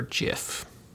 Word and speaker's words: GIF: The G is a soft G for GIF.